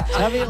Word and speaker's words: Xavi: So I heard the... Xavi like chocolate. – Yes, okay, thank you very much. – Xavi like chocolate.